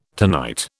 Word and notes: tonight: British English

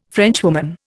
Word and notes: Frenchwoman: Indian

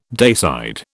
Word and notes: dayside: British English

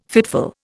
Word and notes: fitful: Indian

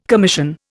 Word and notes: commission: Indian

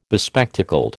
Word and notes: bespectacled: American English

